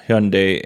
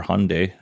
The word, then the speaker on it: Hyundai: And South Korea, home of Hyundai and Kia, or Hyundai, depending on how you want to pronounce that, they're using the CCS connector, CCS1 connector.